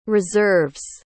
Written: reserves

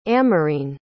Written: amarine

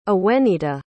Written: awenita

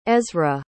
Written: ezra

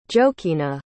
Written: jokina

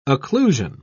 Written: occlusion

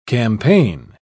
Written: campaign

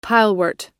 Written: pilewort

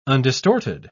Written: undistorted

